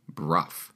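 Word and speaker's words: Brough: My last name is pronounced Bruff.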